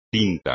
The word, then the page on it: tinto: espagnol